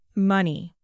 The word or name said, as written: money